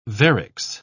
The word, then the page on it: varix: British English